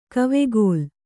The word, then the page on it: ಕವೆಗೋಲ್: kavegōl